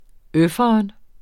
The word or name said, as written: øfferen